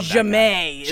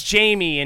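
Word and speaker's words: Ja'mie: Oh, Jamae. His private school girl. – Because yeah. – It's Jamie, and she makes everyone pronounce it Jamae because she's like trying to be all.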